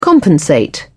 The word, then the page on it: compensate: British English